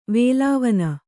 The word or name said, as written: ವೇಲಾವನ